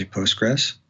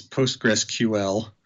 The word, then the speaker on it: PostgreSQL: For me, the QL is silent, so I just say Postgres. – That's one way out. Yes. – Yep. – So I think officially it's PostgreSQL.